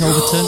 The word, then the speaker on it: Overtoun: I think it's Overton. It's spelled O-V-E-R-T-O-U-N.